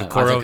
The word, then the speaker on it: Okoro: I kind of even like him more, I think, than Okoro from, I'm probably butchering that, from Auburn. – Isaac Okoro, yeah.